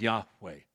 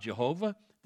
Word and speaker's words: Yahweh: God's name is Yahweh. It's not Jehovah.